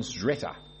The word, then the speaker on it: Szreter: I was interested in it with much, just the kind of strange mind I have, but by two academics in England, Simon Zretta. I'll spell it for you because you won't believe it. S-Z-R-E-T-E-R. Zretta.